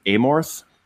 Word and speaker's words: Amorth: It's called The Devil and Father Amorth. Is that right? – Yeah, I think it's Amort. The H is silent.